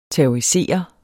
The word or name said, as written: terrorisere